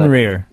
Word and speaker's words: Fenrir: There's another optical drive emulator that's, you know, being developed, and that is called the Fenrir, I think I'm pronouncing Fenrir. – That. – Fenrir, like a wolf, oh. Fenrir. – Fenrir, okay, thank you.